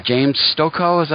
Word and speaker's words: James Stokoe: Now, the artist that you're working with on this project, that's James Stokoe. Am I pronouncing that right? – Yeah, that's right.